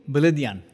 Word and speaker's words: Beledian: The recently published book, Displaced, makes up the last part of the trilogy and is in collaboration with preeminent diaspora author so you'll have to tell me if I'm saying it wrong. Krikor Beledian? – Beledian. – Beledian. Well, Beledian. I'm never going to say it like that, but Beledian. – Yes. You're close. Close enough, I think.